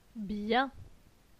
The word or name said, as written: Bien